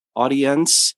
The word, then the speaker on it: Audiense: It's spelled a little funny. I think it's AUDIENSE, Audience.